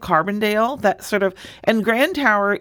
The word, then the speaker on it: Grand Tower: I bet even people who live in Illinois don't know where Grand Tower is. – They don't. And you know, you said it wrong. It's Grand Tower. – Grand Tower. I got to work on that. – Sorry. – Grand Tower.